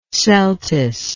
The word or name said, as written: celtuce